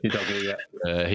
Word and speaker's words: Udogie: Left, we've got, what is it, Udogie? I don't know how you pronounce it. – Udogie, He's coming in.